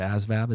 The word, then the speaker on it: ASVAB: I took the ASVAB. Is that how you say it? Yeah.